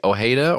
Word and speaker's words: Ojeda: She says, I don't know how to pronounce O-J-E-D-A, Ojeda, or is that, would you say Ojeda for that? – Is that Richard? – Sounds right, yeah.